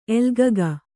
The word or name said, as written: ಎಲ್ಗಗ